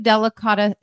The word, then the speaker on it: delicata: So what does a delicata, am I saying it right? Delicata squash. – Totally.